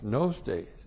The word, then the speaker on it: gnostes: G-N long O-S-T long E-S, gnostes.